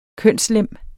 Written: kønslem

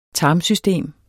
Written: tarmsystem

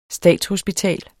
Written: statshospital

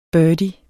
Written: birdie

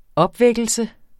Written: opvækkelse

